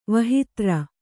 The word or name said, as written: ವಹಿತ್ರ